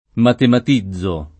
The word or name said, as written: matematizzare